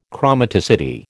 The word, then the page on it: chromaticity: American English